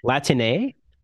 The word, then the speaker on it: Latine: Latine? – Yeah, with an E.